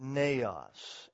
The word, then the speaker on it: neos: the Greek word neos, N-E-O-S, if you want to spell it.